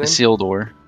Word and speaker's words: Isildur: Like M-E-L-L-O-N? – Yep. Isildur? How do you say the name? – Isildur.